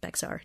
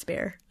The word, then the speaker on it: Bexar: and I was like Bexar, and he was like Bear, and Bexar. And he said, no, you're saying it wrong. It's Bear. – It looks like Bexar sounds like Bear.